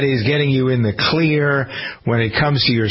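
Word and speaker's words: Xlear: There's a system from a company called Clear. It isn't spelled with a C, it's spelled with an X. X-L-E-A-R.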